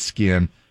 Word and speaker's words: SKKN: No, it's the middle one. S-K-K-N. It's spelled weird, but it's skin.